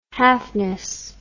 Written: halfness

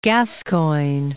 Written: Gascoigne

